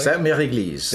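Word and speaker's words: Sainte-Mère-Église: And, of course, we went this morning to St. Mary Eglise. Did I pronounce that correctly? – St. Mary Eglise, the Church of the Holy Mother.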